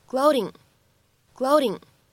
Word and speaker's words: gloating: 在美式发音当中呢，此时这个单词当中的字母 t，它呢会做一个典型的闪音的处理，所以呢，这个单词我们在美式发音当中呢会读成 gloating，gloating。